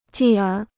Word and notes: 继而: jì ér

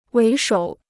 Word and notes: 为首: wéi shǒu

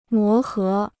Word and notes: 磨合: mó hé

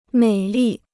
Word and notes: 美丽: měi lì

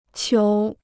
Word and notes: 球: qiú

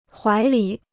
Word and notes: 怀里: huái lǐ

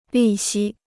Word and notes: 利息: lì xī

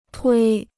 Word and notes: 推: tuī

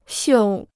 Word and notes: 绣: xiù

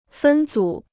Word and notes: 分组: fēn zǔ

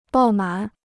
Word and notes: 爆满: bào mǎn

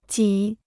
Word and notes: 几: jǐ

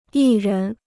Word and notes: 艺人: yì rén

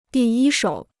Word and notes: 第一手: dì yī shǒu

